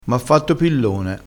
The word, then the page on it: pillone: dialettale